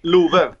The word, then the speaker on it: Luva: It's actually a Swedish name, Luva.